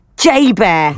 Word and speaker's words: JBER: The bear was caught on camera entering the Exchange shopping mall on the base, right, which is commonly known by the acronym J-B-E-R, pronounced J-Bear. Yeah, it's that ironic.